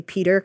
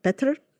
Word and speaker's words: Petr: I know, and he was being really sweet by forcing himself to say Peter, because I know that's not how they pronounce it. – Yeah. – It's Petr? – Yeah. – Petr, something like that.